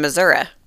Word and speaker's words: Missouri: Missouri. – Ah. – Which I've been to. – I like that you pronounce it Missouri.